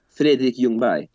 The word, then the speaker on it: Ljungberg: Fredrik Ljungberg.